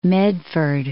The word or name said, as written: Medford